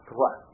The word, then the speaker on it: Troyes: Troyes. Se escreve Troyes, mas se chama Troyes. Fala Troyes.